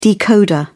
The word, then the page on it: decoder: British English